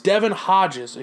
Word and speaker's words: Hodges: Moving on, you have the Steelers, who are going to be starting third-string quarterback Devin Hodgins. How do you even say the guy's name? Devin Hodlin. It's Devin Hodges, excuse me. That's how you say his name.